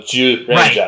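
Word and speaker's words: Zyuranger: Oh, it's spelled Z-Y-U, but in Japanese, it's Jew. So, Jew Ranger.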